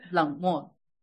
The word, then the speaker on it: 冷漠: 冷漠, is that how you say it? – 冷漠。